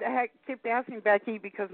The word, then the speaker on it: Becky: Hi, this is Becky. How are you? – Fine. This is who? I'm sorry. – Becky. – Jackie. Well, Jackie. – Becky. – Welcome to the- Becky. Hi. Becky. – Jackie, spell it. – B-E-C-K-Y.